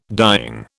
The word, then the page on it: dying: British English